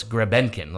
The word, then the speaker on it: Grebenkin: Yeah, it's interesting because as of like 2 years ago, it was spelled and pronounced Grebyonkin, like Greb Yonkin, Yonkin. – Yonkin. – Now it's Grebenkin, like Grebenkin.